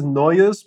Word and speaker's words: Nojus: My name is Nojus Moganis. That's how it's pronounced in Lithuanian, which nobody should attempt. It's very difficult.